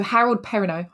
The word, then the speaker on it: Harold Perrineau: Is it Harold Perrineau? – That's how I would pronounce it. – Okay, so Harold Perrineau, hopefully, as Boyd, the self-appointed sheriff of the town.